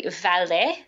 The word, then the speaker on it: Valais: No, so I'm probably saying it incorrectly. Valais, so it's V-A-L-A-I-S.